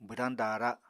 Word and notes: bwdañ daara: apatani

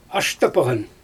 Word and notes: ashtypaGan: Xyzyl

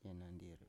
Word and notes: yenandir: Biak

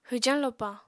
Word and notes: hwjañ lopa: apatani

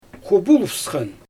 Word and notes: xubulvsxan: Xyzyl

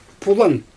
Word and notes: pulan: Xyzyl

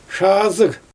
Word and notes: ʃaazɯɣ: Xyzyl

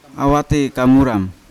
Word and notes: awate kamurang: Yawa; Unat

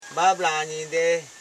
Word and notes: babla: ba.bla

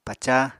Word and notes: pacá: Apatani

